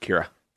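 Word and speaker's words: Qi'ra: It's Qira. Is it Qira? – I believe it's pronounced Kira.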